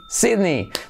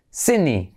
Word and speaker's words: Sydney: ¿Cómo se pronuncia? Sydney, Sydney. Terminación muy parecida, por ejemplo, en Disney. Disney como Disney Channel, o la moto Harley, ¿vale? Es como I, Harley, Disney, Sydney, keep it in mind, alright?